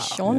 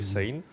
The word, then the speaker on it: Sean: again, do I get to call you Scene? That's how it's spelled. – but that's not how it's pronounced. It's a Gaelic name.